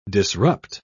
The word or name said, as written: disrupt